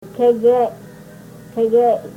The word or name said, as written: kegey